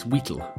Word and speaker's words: Wheatle: Brixton Rock by Alex Wheatle, not Alex Wheatley, as I incorrectly pronounced.